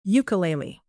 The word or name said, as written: ukelele